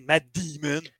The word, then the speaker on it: Matt Damon: Matt Damon, Matt Damon. Le A se prononce pas comme un I en anglais, c'est Matt ah, Damon.